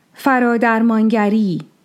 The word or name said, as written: Faradarmāngari